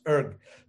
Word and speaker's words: Rig: So Rig in Sanskrit, actually pronounced Urg, but Rig means a hymn.